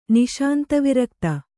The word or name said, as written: ನಿಶಾಂತವಿರಕ್ತ